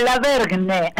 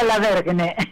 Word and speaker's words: Lavergne: Te aclaro, mi apellido es Lavergne. – Ah, Lavergne, ahí está. – Es difícil de pronunciar, Laura Lavergne.